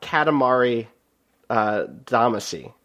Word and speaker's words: Katamari Damacy: Think about Katamari Damacy.